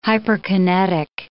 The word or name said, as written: hyperkinetic